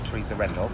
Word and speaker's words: Teresa Rendell: Because you're writing with a young lady called Teresa Rendell. – Yes, yeah. – Did I pronounce it? – You did, yeah, yeah.